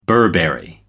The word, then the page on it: burberry: American English